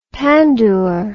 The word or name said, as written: pandoor